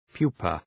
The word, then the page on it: pupa: ‘pju:pə